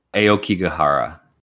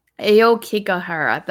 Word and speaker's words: Aokigahara: Aoghikehara Forest. Do you know how to say this? – One second. – Aoghikehara Forest. – Aokigahara. – Mm-hmm. Aokigahara. That sounds way better.